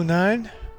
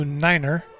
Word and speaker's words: Niner: I am Blue Nine. Blue Niner is the correct pronunciation. Blue Niner.